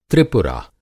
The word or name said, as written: त्रिपुरा